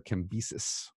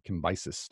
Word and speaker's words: Cambyses: So this is Cyrus's father, Cambyses, or Cambyses, depending on how you want to pronounce the Greek.